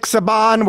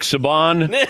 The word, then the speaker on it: Saban: Jumbo Fishy, Nick Saban, we're on to you. – Just want to say Nick Saban. No, it's Saban. Nick it's Saban. Saban.